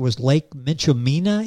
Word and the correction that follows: Minchumina: Am I saying that correctly? – Yeah, Lake Minchumina. It's Minchumina, okay.